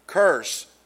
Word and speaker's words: curs: Not necessarily curse like in a curse or cursing, but curse. The word is C-U-R-S.